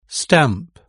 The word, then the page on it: stamp: British English